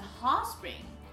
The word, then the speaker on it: hot spring: It was at the hot spring. Hot spring. Hot spring 这个词在发音的时候，注意一下这个 t 可以略读或者轻读。